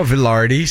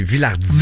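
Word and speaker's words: Vilardi: Il évoluait sur un trio formé de Mark Scheifele et Gabriel Villardi. Pardon. – Villardi. – Ouais, j'avais envie de dire Villardi comme les commentateurs français. – C'est pas Villardi, c'est Villardi.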